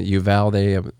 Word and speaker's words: Uvalde: Uvalde, I believe, is how people are pronouncing it. Uvalde, however you want to say it.